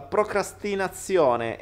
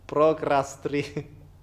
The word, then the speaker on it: procrastinazione: La procrastinazione è una dipendenza? no, è una parola difficilissima da pronunciare. Procrastri.